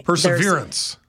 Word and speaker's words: perseverance: Perseverance, I've also been mispronouncing. – Perseverance. – There's no extra R in there, which is why it was saying it was misspelled. – That is correct.